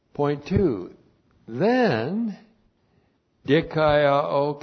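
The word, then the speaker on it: dikaiō (δικαιόω): D-I-K-A-I-O, long O, dikaiō.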